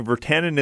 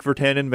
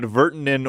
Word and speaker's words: Virtanen: Similar to Virtanen, I've been hearing Virtanen is the Canadian pronunciation, such as Jake Virtanen, Vancouver Canucks player. But Virtanen or Virtanen is the actual Finnish pronunciation.